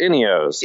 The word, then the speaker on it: Ineos: It's Cheerios, Ineos.